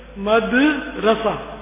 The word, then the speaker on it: مدرسہ: اول تو وہ ذرا تلفظ کی بات, مدرسہ ہمارے عام یہ غلط العوام ہے, مدرسہ نہیں ہے, مدرسہ عربی میں, یہ عربی لفظ ہے, مدرسہ دال پر فتحہ نہیں ہے بلکہ دال ساکن ہے, مدرسہ.